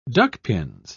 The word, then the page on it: duckpins: British English